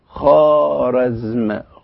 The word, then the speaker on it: خوارزم: إحنا تعودنا أن ننطق هذه الكلمة خوارزم، خوارزم، لكن الإيرانيون ينطقونها وفقا لهذه القاعدة اللي أنا قلتها، يقولوا إيه خارزم، خارزم. ليه بيقول خارزم؟ لسبب بسيط، لأن الواو توسطت الخاء والألف.